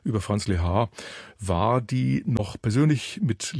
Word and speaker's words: Lehár: Der Name Lehár wird in allen Staaten der Welt anders ausgesprochen. Um es gleich richtig zu stellen, die Betonung liegt nicht auf der ersten, sondern auf der zweiten Silbe, und das H muss natürlich zu hören sein.